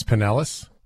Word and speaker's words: Pinellas: And I also think it's Pinellas, in case you're wondering. – Yeah, I got too fancy. It's a Florida pronunciation.